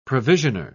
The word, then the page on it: provisioner: British English